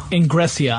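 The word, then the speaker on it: Engressia: Yeah, this kind of leads us to Joe Ingresseria. Ingresia. I'm sorry, I mispronounced that last name. Ingresia.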